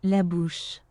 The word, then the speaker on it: La bouche: La bouche.